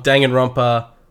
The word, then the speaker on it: Danganronpa: With that all clear and all done, we're going to get right into the prologue of Danganronpa Trigger Happy Havoc.